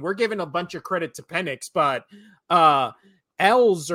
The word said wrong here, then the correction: Ewers: or however the fuck you say his name, that was Quinn Ewers. – I think it's Ewers.